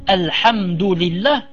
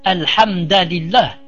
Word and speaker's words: Alhamdulillah: Si c'est par exemple Alhamdulillah, il ne dit pas par exemple Alhamdalillah, il ne dit pas aux règles, elles ne sont, comment dirais-je, elles ne sont obligatoires que pour ceux qui ont les moyens.